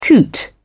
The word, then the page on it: coot: British English